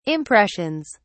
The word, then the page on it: impressions: British English